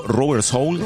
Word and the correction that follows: Rubber Soul: Rubber Soul. – Como alma de goma, eso, ¿no?